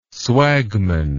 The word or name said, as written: swagman